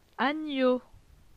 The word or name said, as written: Agneau